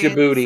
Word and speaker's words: Djibouti: I think there's a silent D in there. I could be wrong. – All right, yes. I'm sorry. – Djibouti. – Silent D's nuts. – Yep, it's Djibouti.